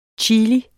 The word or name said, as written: CHILIER